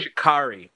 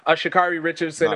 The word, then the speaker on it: Sha'Carri Richardson: Shakari Richardson. – Sorry, what's Shakari Richardson? – Shakari. I just didn't want to say it wrong. Shakari Richardson, of course, is we all know that Shakari Richardson is not going to be able or is going to miss.